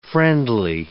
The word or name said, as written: friendly